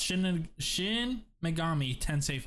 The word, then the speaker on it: Shin Megami Tensei: So it's Shin Megami Tensei 5.